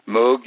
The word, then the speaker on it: Moog: Moog, I have a how you pronounce it, Moog? – Moog synthesizer. Moog, yeah, I love the Moog.